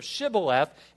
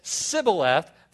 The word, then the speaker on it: Shibboleth: The Ephraimites had something of a lisp, and they couldn't pronounce the H. And so Scripture says, if they said, instead of Shibboleth, if they said Sibboleth, they would kill them.